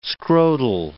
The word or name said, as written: scrotal